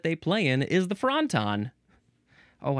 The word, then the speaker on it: fronton: It's like a wonton, but it's a fronton. It's spelled F-R-O-N-T-O-N.